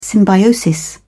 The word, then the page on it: symbiosis: British English